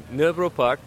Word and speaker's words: Nørrebro Park: Nørrebro Park.